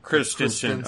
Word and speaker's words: Christensen: Peter Christensen, not Christensen, but Christensen. Okay. – C-H-R-I-S-T-E-N-S-E-N.